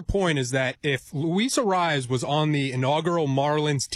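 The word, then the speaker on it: Arraez: Arias? – Arias. – Whatever Arias, it is. Yeah. I mean, Jesus Christ. But Luis, how do you say it Arias. – Again? – Arias.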